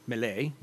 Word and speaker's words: melee: And then, of course, forged weapon item history, melee weapons, or melee weapons, if you want to do the proper French pronunciation, which I never knew.